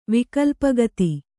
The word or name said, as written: ವಿಕಲ್ಪಗತಿ